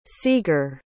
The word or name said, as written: seeger